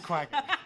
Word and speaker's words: quagga: It is quagga.